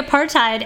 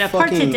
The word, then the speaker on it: apartheid: But the official, it's apartheid. You can also say apartheid. Apartheid, but apartheid, I think, is the official pronunciation.